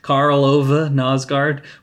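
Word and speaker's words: Karl Ove Knausgård: I recently learned that that's how you say his name. It's like Ove or Ova. Carl Ove Nosgaard.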